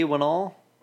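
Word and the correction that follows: Whannell: I'm probably pronouncing it wrong, but I'm saying Lee Wannell. – Wannell? Oh yeah. – W-H-A-N-N-E-L, Wannell.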